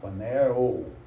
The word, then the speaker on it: phanero: Phanero. Actually has a long O. O. Phanero. And it means to make known.